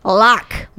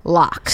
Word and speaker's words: Locke: Locke. – Spell it. – L-O-C-K-E. – It's got a fancy E on the end. – It's got a fancy E. – Oh. – It's an old English lock.